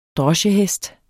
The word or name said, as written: droschehest